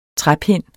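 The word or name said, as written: træpinde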